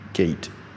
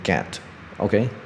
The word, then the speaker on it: gate: 好，这个会延伸到很多啊别的字，比如说 gate，Bill Gate，OK，啊 G A T E gate。这个字就是啊闸门的意思嘛，OK，哦铁那个铁栏啊之类的，OK 就 gate，OK，好，很多人就给我念成 get，OK，好 gate，OK，A T E，OK，记得 OK，所以说啊啊在字里面找你会的字，在不会的字找会的字是这招是非常有效，我觉得就是我太爱这一招了，真的是很很万用，OK，所以大家记得记得，OK，好啊那。